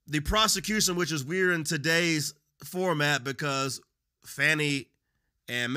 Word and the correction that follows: Fani: Mr. Wade Fani, excuse me, I always mispronounce her name.